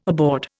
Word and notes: aboard: Indian

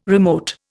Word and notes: remote: Indian

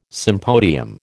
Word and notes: sympodium: American English

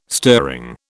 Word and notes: stirring: British English

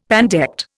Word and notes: pandect: Indian

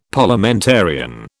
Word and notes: Parliamentarian: British English

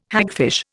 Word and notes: hagfish: Indian

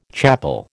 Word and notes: chapel: American English

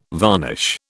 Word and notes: varnish: British English

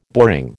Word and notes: boring: American English

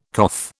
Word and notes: coth: British English

